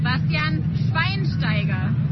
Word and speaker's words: Bastian Schweinsteiger: Bastian Schweinsteiger.